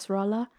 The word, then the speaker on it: Nasrallah: How would you pronounce Nasrallah? – That? Nasrallah.